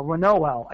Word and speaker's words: Reinoehl: R-E-I-N, like in reindeer, R-E-I-N-O-E-H-L, Renoel. I think I'm saying it right.